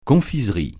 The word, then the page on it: confiserie: francais